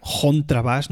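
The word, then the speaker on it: Kontrabass: Porque si bien ellos utilizan muchas veces la, palabra alemana en sí, contrabas, bueno, con pronunciación suiza sería contrabass, ¿no? Der contrabass.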